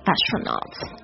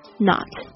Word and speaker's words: astronaut: A-S-T-R-O-N-A-U-T astronaut，特别注意一下它的发音，那个重音是在 A 上面 astronaut，这个 A-U 这个发音是发这个 R 的音哦 astronaut。